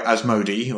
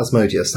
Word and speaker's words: Asmodee: So Fantasy Flight is owned by Asmodee, or however you pronounce that. – Definitely not Asmodeus, that's right. As not Asmodeus.